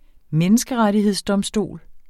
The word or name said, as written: menneskerettighedsdomstol